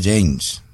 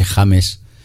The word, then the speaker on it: James: Muy bien traído, además, porque debo de reconocer que en pasados programas le habíamos intentado españolizar el apellido al tal José James, pero además, como bien has pronunciado tú y alguna seguidora del programa... – Pronuncié también la semana pasada. – Sí, sí, pero nosotros nos empeñamos un poco en ese James, dejándonos llevar ahí por otros James de esta vida.